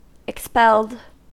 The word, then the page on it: expelled: American English